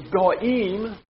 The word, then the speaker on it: goyim: If you hear a Hebrew word spoken in the last two letters, it is im. That's how you say it. Im. That's like putting an S on a sentence, on a word, when we want to make it plural. So it's goyim.